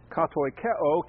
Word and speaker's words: katoikeo: katoikeo, K-A-T-O-I-E-O is how you spell it. K-E-O, excuse me. K-A-T-O-I-K-E-O, katoikeo.